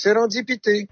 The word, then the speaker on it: serendipity: Et c'est le mot serendipity que je prononce serendipité.